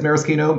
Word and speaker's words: maraschino: Yeah, it's pedantic to pronounce it correctly as maraschino, maybe, but it's like the bruschetta bruschetta thing.